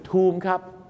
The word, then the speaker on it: Tomb: ออกเสียงว่าทอมไรเดอร์ถ้าทอมไรเดอร์ก็ผู้หญิงขี่ทอมถ้าทูมไรเดอร์ก็ผู้หญิงขี่สุสานแล้วก็จะบุกไปขี่สุสานทำไมครับหลายคนนะครับงงไงครับเพราะว่า BOMB ก็คือบอมพอเห็นคำว่า TOMB ก็เลยนึกว่าทอมไม่ใช่นะครับเห็นไหมครับไม่มีมาตรฐานครับภาษาอังกฤษทูมครับเหมือนคางทูมนะฮะนั่นแหละครับทูมก็คือสุสานใหญ่ๆส่วนคำว่า Raider เขาสะกด R-A-I-D-E-R